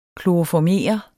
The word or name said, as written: kloroformere